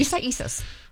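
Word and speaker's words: Isaias: Isai Isis? – You got it. – I'm saying it right. – Isai Isis.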